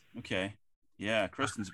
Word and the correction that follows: Kirsten: boat. Kirsten's boat. – That's right. Kirsten. – Yep. – Pronounce it Kirsten.